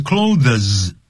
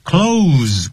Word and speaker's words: clothes: I gave away some of my clothes，记住 clothes 千万不要念 clothes，那个 th 不念出来的 clothes，跟关门的关门的那个 close the door，那个 close 的发音是一模一样。我要把我一些衣服呢捐出来，捐给慈善机构。